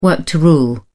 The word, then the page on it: work to rule: British English